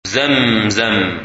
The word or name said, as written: ZamZam